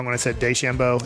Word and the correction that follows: DeChambeau: It's DeChambeau. – Just the D. Not day.